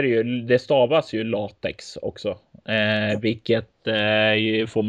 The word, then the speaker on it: LaTeX: och sen nämnde du ett annat program som jag alltid har kallat för latex, eh, inte latesh eller vad du latesh. Ja, jag, jag uttalade det fel jättelänge också.